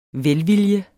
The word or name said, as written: VELVILJENS